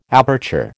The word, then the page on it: aperture: American English